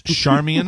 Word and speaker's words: Charmian: It's Charmian. – I believe it is Charmian Carr.